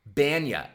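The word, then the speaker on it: Bania: Brooklyn Cyclones Seinfeld, Kenny Bonilla talking bobblehead. – Banya. It's pronounced Banya, David.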